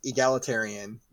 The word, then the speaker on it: egalitarian: Egalitarian. No idea what the hell that means. – Like, equal in sexes. – Oh, equal. Okay, so that's how you pronounce that.